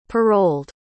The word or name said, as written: paroled